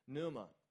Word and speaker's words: pneuma: The P is silent, it's the word pneuma.